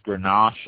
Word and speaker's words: Grenache: Garnacha, G-A-R-N-A-C-H-A, Garnacha. This is probably one of the world's most widely cultivated red grapes. It's pronounced Grenache in a lot of areas, so the A at the end with an E at the end.